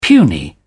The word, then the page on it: puny: British English